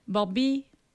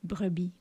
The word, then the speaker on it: brebis: » Barbie, c'est brebis, oui, des brebis, mais on déforme un petit peu la prononciation. Donc, c'est encore courant, on dit, chez les éleveurs, une belle Barbie pour brebis.